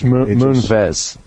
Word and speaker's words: Moonves: And 4 years ago, this guy, Moonves, how do you say it, Moonves or Moonriz? – Moonves. Moonves. He's a male.